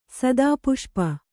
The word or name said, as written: ಸದಾಪುಷ್ಪ